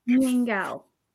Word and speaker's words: nian gao: So we call it nian gao. Nian means year. Gao means cake. So it's year cake. – Nian gao.